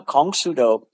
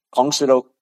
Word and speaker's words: Kongsudo: Now, Kongsudo, that's the Korean pronunciation of the ideograms for karate do. Kongsudo, karate do.